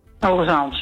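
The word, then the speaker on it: Hoogezand: En het is dus ook Hoge Zand en niet Hoge Zand. – Hoge Zand, ja.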